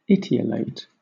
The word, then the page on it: etiolate: British English